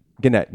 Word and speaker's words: Gannett: And then Gannett, which is a big newspaper conglomerate. – I thought it was Gannett. – Gannett, you are correct. – I did too. – It is Gannett.